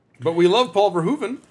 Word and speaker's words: Verhoeven: Also, the Dutch OE sounds like an English OO, so Verhoeven rhymes with Pershoeven. – So Verhoeven. – Verhoeven.